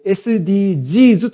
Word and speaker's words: SDGs: で、このサステイナボーデベロップメントゴールズとこう訳される持続可能な開発目標、訳して SDGs と訳す、言うんじゃなくて SDGs と言います。最後の S は複数形の S なので頭文字の S じゃないので S と発音しないで G's と、さらにそして小文字で書くんですね。